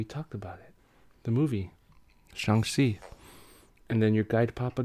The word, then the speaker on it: Shang-Chi: I think my son just corrected me. How do you say it? – Shang-Chi.